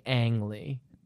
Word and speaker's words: Ang Lee: It occurs to me, do you pronounce his name Ang Lee? – Probably. You're the one who's been saying Ong. – I don't know where I came up with Ong. – It's almost certainly Ang Lee.